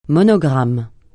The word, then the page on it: monogramme: francais